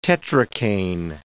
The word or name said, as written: tetracaine